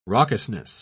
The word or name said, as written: raucousness